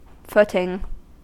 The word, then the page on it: footing: American English